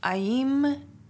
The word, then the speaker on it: Aim: Aim is a Bija mantra. It's spelled A-I-M and pronounced Aim.